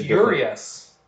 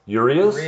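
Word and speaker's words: Urías: I still can't say his name. I think they say Urias. – Urias? – Yes.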